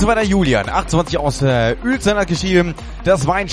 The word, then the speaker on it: Uelzen: Und zwar wird es Uelzen ausgesprochen. UE Uelzen. Wunderbar, vielen Dank, liebe Telegram-Gruppe.